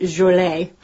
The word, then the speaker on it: Joliet: Now you might hear that last name pronounced Joliet.